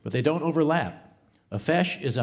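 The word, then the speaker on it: feis: A FESH, pronounced FESH, is the name for an Irish dance competition.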